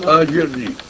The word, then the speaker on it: أجرني: الهمزة فوقها سكون لو أردنا أن نبتدئ بها كيف تلفظ؟ كلمة أجرني لوحدها. – أجرني. – أجرني يعني تحول السكون. – إي نعم. – أجرني. – لأنه السكون لا يبتدأ به.